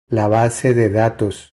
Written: La base de datos

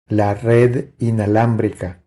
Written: La red inalambrica

